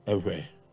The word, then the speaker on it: Ewe: Ewe.